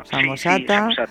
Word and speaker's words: Samosata: Hoy hemos conocido a Luciano de Samosata, se dice así, ¿verdad? Samosata.